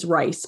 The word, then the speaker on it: Ryce: And my last name is Ryce, but it's spelled R-Y-C-E.